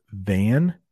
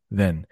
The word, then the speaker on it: than: So it's pronounced than, T-H-A-N, but you will hear a lot of native English speakers say then. When you write it, you should say than, but I like apples more than I like bananas. You hear that? It sounds like then, but I'm actually saying than.